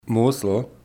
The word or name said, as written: Mosel